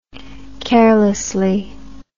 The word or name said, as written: carelessly